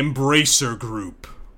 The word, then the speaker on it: Embracer Group: The Embracer Group. Say it like that. Embracer Group.